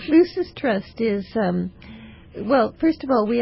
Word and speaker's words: Lucis Trust: What is the meaning of the name Lucis Trust? – Well, first of all, thank you for pronouncing it properly. So many people say Lucis Trust.